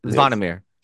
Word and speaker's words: Zvonimir: Zvonimir Visic. – Yes. – Zvonimir. So it's like the Z and the V are like one letter together.